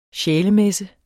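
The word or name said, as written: SJÆLEMESSE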